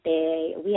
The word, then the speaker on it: Oui: If anyone wanted to know the song, it's by a man named Jeremiah, J-E-R-E-M-I-H, and it's called We, like, but it's the French We, O-U-I, the place is like, there's no We without you and I, because it's spelled O-U-I, pronounced We, but not like the W-E.